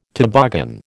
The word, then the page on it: toboggan: American English